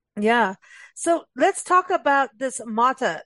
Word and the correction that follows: mate: I'm not even pronouncing it right. – Mate, mate. – Mate. Yes, Yes.